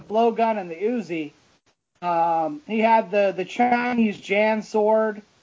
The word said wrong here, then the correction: jian: It's Jian. Yeah. – Maybe I'm pronouncing it wrong. J-I-A-N.